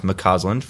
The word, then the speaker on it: McCausland: It's pronounced Mccausland for those that are interested.